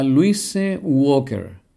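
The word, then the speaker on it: Luise Walker: Me refiero a Louise Walker, sí, pronunciado a la inglesa, aunque era austríaca.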